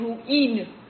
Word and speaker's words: روئین: حالا این کرنای و روی، خب روی هم که جنس همونه که الان ما میگیم روحی، مثلا میگیم قابلمه روحی، از رویه، یعنی در اصل باید بگیم قابلمه روئین، ولی خب روئین چون در زبان سخته و زبان تلفظش به سمت سهل سازی همیشه میگه، میریم میگیم قابلمه روحی.